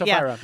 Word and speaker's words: Ophira: No, it's Ophira. I'm pretty sure it's Ophira.